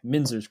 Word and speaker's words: Minzer: Is it Minzer? Is it a short I, or is it Minzer's correct, yeah.